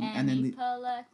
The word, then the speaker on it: Aniplex: Aniplex.